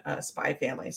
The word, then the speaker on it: Spy X Family: I don't know why the X is silent. Silent in Hunter Hunter is silent in Gunsword. I always thought it was Gun by Sword, but it's Gunsword. And is silent in Spy Family.